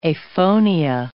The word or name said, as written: aphonia